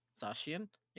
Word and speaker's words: Zacian: Z- Zashian. Zashian. – Zashian. – Zacian, yeah, uh, for Master League since uh, I've I've been pushed into this this Master League role for our group.